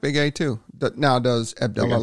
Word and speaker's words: Abdullah: His name, the way that you actually pronounce his name is Abdullah, by the way.